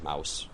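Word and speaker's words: Maus: And to be fair, it's M-A-U-S, which we all pronounce as mouse.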